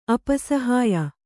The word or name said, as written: ಅಪಸಹ್ಯ